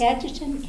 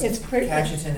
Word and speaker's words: Cajetan: And he said, so again, this is Cajetan saying. – Is it Cajetan? – Yeah, I've heard it pronounced Cajetan as well.